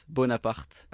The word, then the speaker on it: Bonaparte: Okay, so it's Bonaparte. – Okay. – With a strong R.